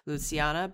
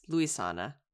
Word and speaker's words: Luisana: I think because I can't spell, I wrote it in my notes as Luciana, but it's Luisana. So apologies to her. I've been saying her name wrong this entire time.